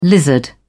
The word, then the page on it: lizard: British English